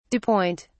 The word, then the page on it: dew point: British English